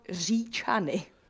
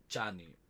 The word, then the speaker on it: Říčany: Czech it's called Říčany. – Říčany. – Almost. – almost. Říčany. There we go. Phonetically. – Yeah, R with a little hook above it. That's it.